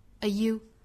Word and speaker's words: are you: 第一句歌词里的 are 和 you 我们可以做弱读，我们读成 a you，更多的力呢放到后面的 going 上， are you going， are you going。